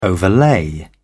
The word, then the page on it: overlay: British English